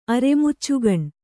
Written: ಅರೆಮುಚ್ಚುಗಣ್

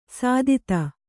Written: ಸುದತಿ

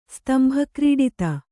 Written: ಸ್ತಂಭಕ್ರೀಡಿತ

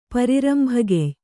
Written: ಪರಿರಂಭಗೆಯ್

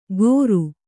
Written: ಕೋರು

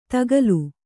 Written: ದಾಕಲು

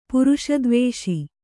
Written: ಪುರುಷದ್ವೇಷ

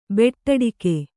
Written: ಬೂಟಾಟಿಕೆ

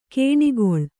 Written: ಕಣೆಗೊಳ್

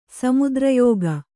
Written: ಸಮುದ್ರಯೋಗ

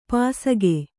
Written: ಪಾಸಿಕೆ

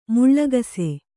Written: ಮೂಲಗಿಸು

